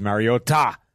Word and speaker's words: Mariota: It is Mariota. And if you don't say it like that, you're going to be in trouble.